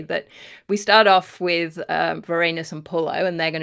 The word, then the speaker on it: Vorenus: I called him Warrenus as well, because that's the Latin pronunciation. And then I compromised and called him Vorenus when I was reading out.